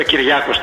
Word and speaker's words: Κυριάκος: Κυριακός, όπως είπε και ο προηγούμενος συνομιλητής, Κυριακός. Βέβαια, Κυριάκος τονίζεται, αλλά. – Μισό λεπτό. Είναι Κυριακός ή Κυριάκος? Έχει και αγίου. – Όχι, είναι όσιο Κυριακός είναι, αλλά γιορτάζουν οι Κυριάκοι.